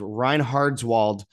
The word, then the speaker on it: Reinhardswald: There's much worse things than that, but continuing on, it says, the Reinhardwald's, it's hard to pronounce, it's Reinhardswald is sparsely populated,